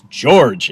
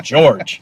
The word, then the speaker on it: Georg: and it's just George. It's George. It's just the Austrian pronunciation of George. I'm like, huh? Because I always thought it would be like G-A-Y-O-R J or something like that. And I was like, no, it's George.